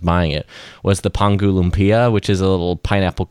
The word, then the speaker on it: Lumpia: My wife, being Filipino, would correct your pronunciation there. – What Lumpia.